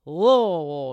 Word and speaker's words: Louisville: Louisville. You got to say it like you have a mouthful of balls.